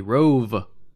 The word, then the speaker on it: Rhuobhe: Yes, I pronounced that correctly. Rhuobhe with a V, Manslayer.